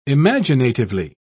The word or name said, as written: imaginatively